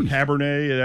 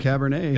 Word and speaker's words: cabernet: Got a piece of peanut butter pie with a nice cabaret. – Oh, good grief. – Or cabernet, that's how you say it. – Yeah, cabernet.